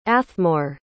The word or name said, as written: athmore